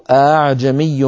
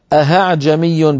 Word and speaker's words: أعجمي: السؤال الثاني لياسر يقول كيف تلفظ كلمة أعجمي برواية حفص وهل صحيح أن تلفظ أهعجمي بإبدال الهمزة هاء في قراءة عاصم؟ لا يا أخي الكريم، لا في قراءة عاصم ولا في غيرها من القراءات لا يصح ولا يجوز ويحرم إبدال الهمزة هاء إلا برواية، وليس هناك رواية.